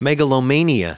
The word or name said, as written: megalomania